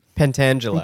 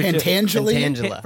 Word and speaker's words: Pentangeli: Pentangela. – Godfather Pentangela.